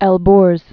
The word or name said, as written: Elburz Mountains